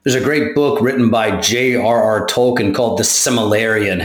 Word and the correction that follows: Silmarillion: Have you read this book or no? The the Silmarillion. – There you go. I never pronounce anything right.